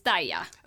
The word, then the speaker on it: Dai-Ya: I hope I'm pronouncing your name well. And hi, how are you? – Hi, I'm fine, and it's Dai-Ya.